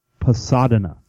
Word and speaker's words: Pasadena: I think that's how you pronounce that. – Yeah. Pasadena. – Pasadena.